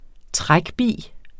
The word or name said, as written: Bi